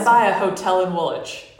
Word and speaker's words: Woolwich: Yeah, it's spelled Woolwich, but it's pronounced Woolwich.